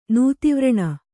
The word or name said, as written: ನೂತಿವ್ರಣ